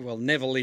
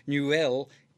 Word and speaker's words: Neuville: Well, Thierry Neuville, as we would say it Neville in English, but Neuville in French, I think, is the correct pronunciation.